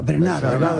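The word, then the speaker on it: Bernhardt: Tienes que pronunciar a la francesa, Bernard.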